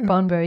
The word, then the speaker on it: Bonbo: So if somebody has heard something on this show and they want to reach out to you, the business name is Bonobo. – Bonbo, yeah. Bonbo, it's like Bon sorry. Bon without the second N. Don't worry, I've not heard anyone else pronounce it correctly.